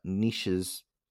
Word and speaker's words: Nietzsche: Leopold was particularly fascinated by Friedrich Nietzsche's, sorry, it's a German name.